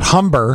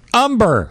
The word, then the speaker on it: Humber: And by the way, he got his name, the pronunciation right, too, the silent H. – Mm-hmm. Not Humber. – Not Humber. – Umber.